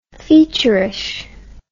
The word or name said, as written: featurish